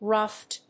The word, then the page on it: ruft: /ɹʌft/; -ʌft